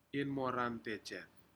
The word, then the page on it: inmorantejed: Aneityum